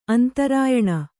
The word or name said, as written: ಅಂತರಾಯಣ